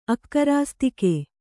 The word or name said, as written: ಅಕ್ಕರಾಸ್ತಿಕೆ